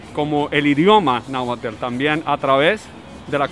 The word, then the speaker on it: Náhuatl: Náhuatl. náhuatl. Se pronuncia la T y la L.